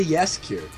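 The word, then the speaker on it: Jaskier: Mas a pronúncia em polonês é Jesker É.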